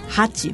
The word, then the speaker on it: 八: Por eso, por eso como 8 en el idioma japonés se pronuncia como hachi, ¿verdad?